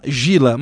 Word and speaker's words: Gila: O nome dela se escreve G-I-L-A, Gila. Aqui em português a gente chama Gila, mas o certo seria Gila, G-I-L-A, e o sobrenome dela Antara, A-N-T-A-R-A, Gila Antara.